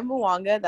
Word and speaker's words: Muwanga: my name is Juanita Muanga. That's spelled J-U-A-N-I-T-A, last name M-U-W-A-N-G-A.